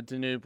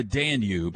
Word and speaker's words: Danube: Good morning from the Danube River north of Budapest. – I believe it's the Danube.